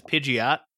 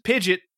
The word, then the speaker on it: Pidgeot: It's like, I used to pronounce the final form of Pidgey as Pidgeot. Turns out it's just Pidget.